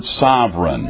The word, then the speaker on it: sovereign: It's spelled, in English, we spell it S-O-V-E-R-E-I-G-N, okay? A pronunciation, you know how it'll have the phonetic pronunciation in a dictionary? That is S-U-V, apostrophe, emphasis on that syllable, right, syllable. I had a teacher teach me that, bad English, E-R-A-N, okay? And so S-U-V, apostrophe, E-R-A-N.